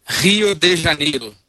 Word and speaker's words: Rio de Janeiro: I hope I'm saying Rio de Janeiro wrong. How do you, just for the record, how is it pronounced? – Rio de Janeiro.